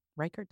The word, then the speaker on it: Reichert: Reichert, did I say that right? Mm-hmm.